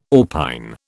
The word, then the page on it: orpine: British English